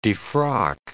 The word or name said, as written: defrock